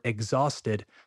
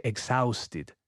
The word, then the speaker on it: exhausted: Important pronunciation tip, by the way. Don't say exhausted, the sound is all okay? Exhausted.